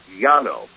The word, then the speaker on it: llano: Now, you can pronounce it a little differently if, for example, you live in what's called the port area, Buenos Aires or Montevideo, but typically the word of the day, L-L-A-N-O, that's how you spell it, L-L-A-N-O, typically you would say Llano.